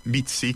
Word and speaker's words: Bitsy: Si scrive Bitsy come bit più S-Y.